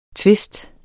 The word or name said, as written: tvist